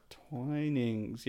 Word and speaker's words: Twinings: Twinings. Yeah, Matt doesn't know how to pronounce Twinings.